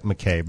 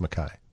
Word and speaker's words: Mackay: It looks like McKay, but it's pronounced Mackay.